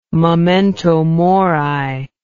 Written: memento mori

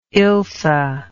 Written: ilse

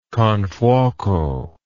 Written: confuoco

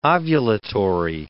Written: ovulatory

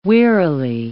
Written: wearily

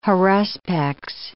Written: haruspex

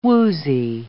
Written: woozy

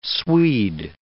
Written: Swede

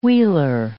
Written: wheeler

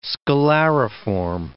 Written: scalariform

